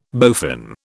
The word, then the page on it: bowfin: British English